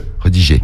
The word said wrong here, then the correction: redirigé: Tu as oublié une syllabe. Redirigé. T'as oublié redigé. Non, c'est redirigé sur Snap.